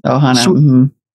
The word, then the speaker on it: Ohana: Am I pronouncing that correctly? – Ohana.